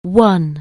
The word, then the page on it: win: anglais